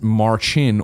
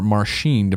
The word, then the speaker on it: Marcin: Marcin is pronounced Marchin or Marchin, depending on the accent.